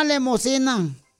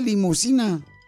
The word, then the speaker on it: limusina: A ver, ¿qué hace una vaca blanca bajándose de una lemosina? ¿Qué hace una vaca? No se dice lemosina, se dice limusina.